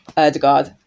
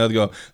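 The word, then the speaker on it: Ødegaard: Quick pronunciation tip from Martin Odegaard. Like, no one's expecting everyone to get this right, but the easiest thing to remember, don't worry about the D at the end.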